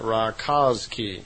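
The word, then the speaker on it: Rákóczi: His real name was Rakoczy. R-A-K-O-C-Z-I. I suppose you would pronounce that Rakoczy.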